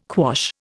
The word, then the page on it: quash: Indian